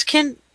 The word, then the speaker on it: Kenda: Was it Kenda? What did you say your name was? – Kenda. – Kenda. As in K? – Yeah.